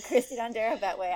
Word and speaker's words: Christie Dondero Bettwy: So you did pronounce my name right. So I'm Christie Dondero Bettwy.